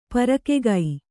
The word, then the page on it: ಪರಕೆಗೈ: parakegai